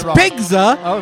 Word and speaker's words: Pigzza: No, it's Pigzza.